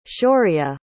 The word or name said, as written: shaurya